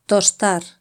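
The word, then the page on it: tostar: Spanish (Spain)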